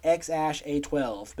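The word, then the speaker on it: X Æ A-12: He identified the second character as Ash, saying it was pronounced X-Ash-A-12.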